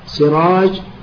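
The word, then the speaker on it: سِرَاج: اثنين، المختلف في صورة حروفه والمختلف في إعجامها مثل سراج وسراح، الأول بكسر السين المهملة وبالجيم، والثاني بسين مهملة وحاء مهملة.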